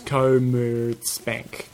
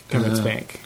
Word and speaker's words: Commerzbank: It's Commerzbank, and it's spelt. – No, that's how you spell it. How you say it is Commerzbank. Commerzbank.